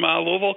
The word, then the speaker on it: Louisville: Hello, calling from Louisville, Kentucky. – Hey. – Hi. – Oh, we know you're a local because you say it right, Louisville.